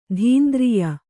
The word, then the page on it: ಧೀಂದ್ರಿಯ: dhīndriya